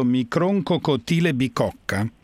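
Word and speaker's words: Microcotyle bicocca: Allora mi dica se lo pronuncio giusto, Microncocotile bicocca? – Sì.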